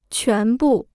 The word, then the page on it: 全部: quán bù